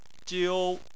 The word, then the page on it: jiu1: chinese